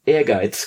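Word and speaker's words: Ehrgeiz: and on Prepare Everybody for having this name pronounced correctly for the first time on Retronaut, Air Guides, God Bless the Ring.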